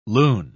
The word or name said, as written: loon